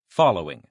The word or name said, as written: following